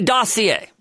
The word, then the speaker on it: dossier: That's a French word. It's spelled dossier. But they say dossier.